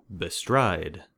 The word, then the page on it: bestride: American English